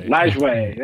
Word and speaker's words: Lajoie: Lajoie.